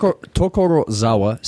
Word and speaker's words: Tokorozawa: One other thing I did want to mention that I found out, the city that this takes place in, Tokorozawa. I'm sure I missed Tokorozawa City in Saitama Prefecture.